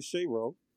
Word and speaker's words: Shero: It's like Shero, which is Roche backwards. And I'm like, wow, I like that. So from then on, people mostly refer to me as Shero.